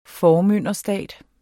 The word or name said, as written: formynderstat